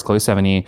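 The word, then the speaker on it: Chloë Sevigny: It stars Chloe Sevigny. I think that's how you say her name. – I think you're doing it. – As doing a great job.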